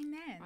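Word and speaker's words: Nairn's: Do you say Nairn? – I do, because that's how it's pronounced.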